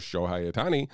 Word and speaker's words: Shohei Ohtani: That's what that dude, the federal authorities charged the former longtime interpreter of Los Angeles Dodger star Shohei Ohtani. Is that how you say it? – Yeah, sure. – Shohei Ohtani? – I think it's Shohei.